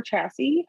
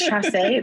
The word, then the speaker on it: Chassay: casting was then done by Dixie Chassay or Chassy, however that might be pronounced. However fancy you're feeling in the moment. – Honestly, it's Chassay. It's like Timothée Chalamet.